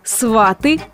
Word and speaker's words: сваты: Ударение на последний слог в слове «сваты» является грубейшей ошибкой. Да и вообще, ударение в этом слове никуда не смещается. А значит, что всегда будет сваты, собрать за столом сватов, дать слово сватам и поблагодарить сватов.